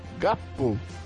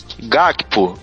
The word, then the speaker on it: Gakpo: Gapo. É isso? A pronúncia? A pronúncia? Gakpo.